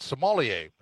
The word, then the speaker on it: sommelier: And wine expert, and I hope I say this right, sommelier, right? Did I say that right? – That is 100% correct.